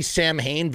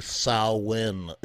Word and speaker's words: Samhain: That's not how you pronounce the name of the band. It's actually Samhain. It's not Sam Hain. Even the band, even Glenn Danzig, he pronounces the name of his band wrong. It's actually Samhain, okay? It's the sow, like a cow, and then in, like you're going in a door, okay?